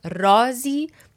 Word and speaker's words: رازی: Okay, and since this is such an important word in the poem, I want to emphasize رازی. The emphasis is on the first word, رازی. If you put the emphasis on the second word, it becomes an altogether different word.